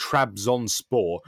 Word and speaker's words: Trabzonspor: Well, the thing is, actually, it's kind of phonetic because the name is literally Trabzonspor. But then, you know, when you put...